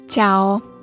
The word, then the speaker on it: Ciao: Ciao. Pisze się C-I-A-O.